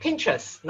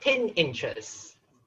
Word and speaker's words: Pinterest: And the website brand called Pinterest, not Pin Interest.